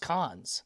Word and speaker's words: Cannes: I think it's pronounced Cons. – Oh, okay. – Not Raising Cane's, because I used to say Cane's also, and someone was like, no, it's Cons.